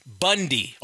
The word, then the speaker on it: Bounde: The problem is, though, their last name is B-O-U-N-D-E. – Bound? – And that's pronounced Bundy.